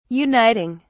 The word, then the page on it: uniting: British English